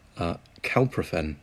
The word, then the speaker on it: Calprofen: Calprofen. I think I'm pronouncing that right. – Right.